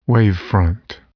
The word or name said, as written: wavefront